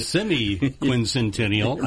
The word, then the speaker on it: semi-quincentennial: Remember how we couldn't pronounce the sesquicentennial? Remember that one? – So this is the semi-quincentennial. I said it right two times.